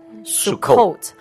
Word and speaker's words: Sukkot: 哎，我我看这个呃，住棚节希伯来文是叫 Sukkot，是叫 Sukkot，是这样念这个发音吗？ – Sukkot Sukkot。 S U K K O T，Sukkot，这希伯来文的意思就是指这个临时的棚舍或者是屋仔，就是那个小屋这样的。